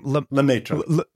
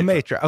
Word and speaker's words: Lemaître: Lemaître. Georges Lemaître. – Lemaître.